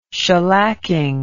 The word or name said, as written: shellacking